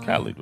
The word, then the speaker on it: Khalid: And I haven't been really digging her music that much lately, but she has a song with Khalid that, how do you say his name? Is it Khalid? It's Khalid, right? – Khalid.